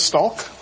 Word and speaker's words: stalk: There's a silent L in this word for silently sneaking up on prey. – What is stalk? – Yes.